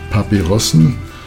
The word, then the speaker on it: Papyrusen: Papyrusen, the actual pronunciation, I think.